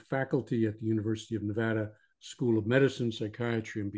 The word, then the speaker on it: Nevada: And I have to pronounce that one properly because my son lives there now, and every time I screw up, he corrects me. So Nevada. Tom is a psychotherapist, marriage and family therapist, and a licensed clinical alcohol and drug counselor.